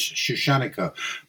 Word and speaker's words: Shushanika: I would say her name is Shushanika, but my life, Russian stress is quite often wrong.